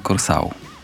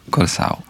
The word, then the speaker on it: Curaçao: Curaçao i rzeczywiście wymawia się Curaçao. – Przepraszam? – Curaçao. – Curaçao. – Curaçao. Ja bym to pierwsze O, znaczy to jedyne O, to tak jeszcze w E wpuścił. Curaçao.